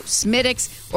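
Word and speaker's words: Smithwick's: You can get a 12-pack of Guinness or a Guinness Extra Stout Smittix or Killian's Irish Red, $13.99.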